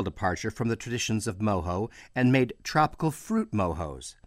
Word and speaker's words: mojo: Many North Americans would look at the word mojo in its printed form and pronounce it mojo. In the Spanish, from where it comes, it is of course pronounced mojo, because the letter J is pronounced as an H, as in jalapeno.